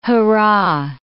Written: hurrah

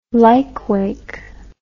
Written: lykewake

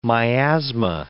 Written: miasma

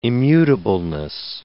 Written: immutableness

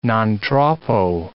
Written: non troppo

